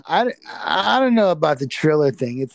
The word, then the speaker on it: Triller: Isn't that what it, or a triller? – Is that still a word? Is that word still usable? – Because it's spelled T R I L L E R. Triller.